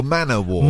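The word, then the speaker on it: man-o'-war: Is it man-of-war or man-o-war? – Man-o-war. Portuguese man-o-war. – But I think you say it like man-o-war.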